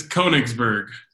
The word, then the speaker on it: Konigsberg: I even asked Adam like 5 times how to say it. Can still say it. – It's Konigsberg. – Konigsberg, damn it.